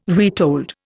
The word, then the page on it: retold: Indian